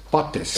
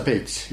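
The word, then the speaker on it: Bates: Noh, inglased kirjutavad teda nagu meie eestlased välja loeme Pates, aga siis hääldatakse teda Peits.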